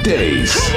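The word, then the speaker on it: Deace: This is Steve Dace.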